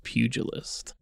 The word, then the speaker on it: pugilist: And he's the Pugulous Pug. – Mm-hmm. – That's all I got. Just this pug man that punches people. – I think it's pronounced like pugilist.